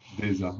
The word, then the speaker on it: DZA: La prononciation, c'est comme Reza, tu vois, c'est DZA. – Ah, DZA, ouais, comme César, comme.